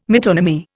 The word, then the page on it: metonymy: Indian